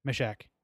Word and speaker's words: Meshach: Okay, how do you say it? – Meshach? – Meshach, and Abednego.